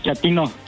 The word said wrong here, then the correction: chatino: ¿Chapino? – No, chatino. – Es como chato, ¿verdad? Chatino. – Sí, azunero, como chato, chatino.